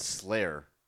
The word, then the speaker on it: Sleigher: They make a seasonal beer in the wintertime called Slayer. – Oh. – Like the band. – Like the band, but it's spelled like a sleigh, like S-L-E-I-G-H-E-R. – I before like, E, except and, after C.